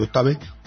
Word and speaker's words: Octave: Octave, tal cual está escrito, no sé cómo se pronuncia. Octave. – Uh-huh. – O-C-T-A-V-E. ¿Cómo se pronuncia eso? – Pues Octave. – Octave, ¿no? Bueno, pues Octave o GNU Octave es un programa libre para realizar cálculos numéricos.